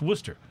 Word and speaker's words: Worcester: It's spelled like Worcester, like W-O-R-C-H-E-S-T-E-R, right? But it's pronounced Worcester.